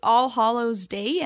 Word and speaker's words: All Hallows Day: So like all, how would you say it? – I think it's All Hallows Day. And then there's All Hallows Eve, I believe.